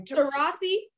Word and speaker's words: Taurasi: Taurasi.